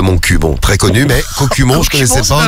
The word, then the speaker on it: Cocumont: Ça s'écrit Cocu comme Cocu. – C'est ça, Cocumont.